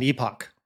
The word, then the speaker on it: epoch: They keep saying like epoch time, which E-P-O-C-H, which is basically time that's relative to an event. – As an epoch. Well, I just always say epoch. – It's pronounced epoch. – I've been saying it wrong for years.